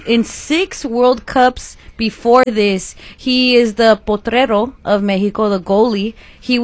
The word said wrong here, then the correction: portero: Did I say it right? – Portero.